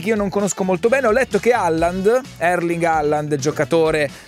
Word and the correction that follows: Haaland: Si pronuncia Holland.